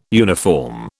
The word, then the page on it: uniform: British English